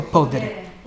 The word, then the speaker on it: podere: a trattenere parte del prezzo del come si pronuncia questo? Podere o podere? Podere. Podere. grazie.